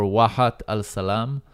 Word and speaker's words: Wahat al-Salam: Noam hails from quite a unique place in Israel called Neve Shalom or Wahat al-Salam. Probably doing an awful job pronouncing that. – It's actually pretty good.